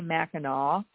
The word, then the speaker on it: Mackinac: It's spelled Mackinac, but they say Mackinac,